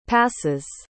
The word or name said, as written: passes